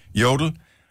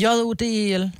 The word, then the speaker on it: Jodel: Så jodel. – Jodel kan man også sige. Men jeg tror bare, det udtales jodel. Jeg ved ikke, om det er tysk eller hvad fanden det er.